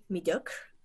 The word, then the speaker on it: mediocre: kiepski, przeciętny przykład zdania I don't want to be mediocre. Jeżeli wymawiam to źle, to mnie możesz poprawić, bo nie jestem pewna e, wymowy tego słowa. – Jest poprawnie.